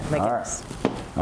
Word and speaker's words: MacGuineas: Did I say that right, MacGuineas? You said it, MacGuineas.